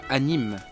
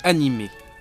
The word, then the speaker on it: animé: On ne dit pas « anime », mais « animé ».